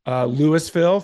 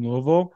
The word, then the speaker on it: Louisville: Don't ever say that to someone from Kentucky, specifically from Louisville. It's basically pronounced Lou, like L-E-W, Vol, V-U-L, Louisville. That's how you say it and make it sound like you're a local.